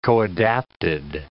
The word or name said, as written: coadapted